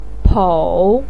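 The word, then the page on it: pou3: tone 3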